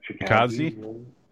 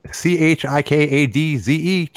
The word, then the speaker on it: Chikadze: Giga Chickadee is the best I can figure out how to say it. – Chikadze? – Yeah. No, Chickadees. Chikadze, chickadees.